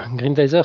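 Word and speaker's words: Grendizer: Ouais, Grindizer, c'est le nom original de Goldorak.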